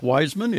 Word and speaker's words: Wiseman: My name is spelled W-I-S-E-M-A-N, just like it sounds. Most Jews spell it W-E-I-S or W-E-I-Z. Dr. Chaim Wiseman, I believe his spelling was W-E-I-Z,